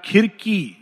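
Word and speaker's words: Circe: यहां फिर से एक और आ गया इसका एक्चुअली प्रोनाउंसिएशन सिरसियन नहीं है इसका प्रोनाउंसिएशन है सिरसी सिरसी सिरसियन या खिरकी ये ग्रीक प्रोनाउंसिएशन इज खिरकी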